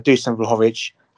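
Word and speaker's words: Dušan Vlahović: And Dusan Vlahovic, and yes, I'm going to pronounce him that way, Andy, because I don't give a what Google says.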